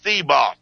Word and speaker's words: Thibault: And then the FBI guy, Tim Thebold, they're trying to make it sound like it's the Tim Tebow. But it's Thebault. I call it Thebault. That's the way it's spelled.